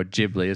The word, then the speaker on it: Ghibli: But I tend to cater towards more Ghibli, or Ghibli, as it's properly announced.